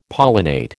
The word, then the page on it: pollinate: American English